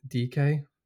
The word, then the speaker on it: Dike: Most notably was, was the guy called Daryl, I think his name is Daryl DK, or it's spelled D-I-K-E, who's come to Barnsley actually in the Championship.